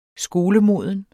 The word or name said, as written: skolemoden